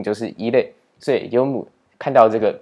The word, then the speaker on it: 読む: 接着我们再来看第四个动词，第四个动词它念作読む，